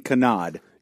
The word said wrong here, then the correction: cannonade: He's leaving out a syllable.